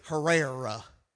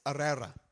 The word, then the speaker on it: Herrera: You're not supposed to pronounce the H. Us hillbillies are used to pronouncing the H. We would say Herrera, right? But it's Herrera, right?